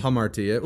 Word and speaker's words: hamartia: All right so the the word in scripture is um, hamartia which is sin how do you say that? – Humartia? hamartia. – Well, hamartia. I always clarify this.